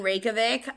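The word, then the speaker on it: Reykjavík: I am going to stay in Reykjavik, and I'm probably going to do, like, a day or two of just, like, walking around exploring Reykjavik.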